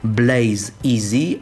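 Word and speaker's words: Blaze EZ: Ja powiem, że ona wymawia się po prostu Blaze Easy, a pisze się Blaze spacja duże E, duże Z.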